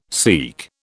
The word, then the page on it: SEAQ: British English